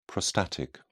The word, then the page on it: prostatic: British English